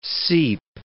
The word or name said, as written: seep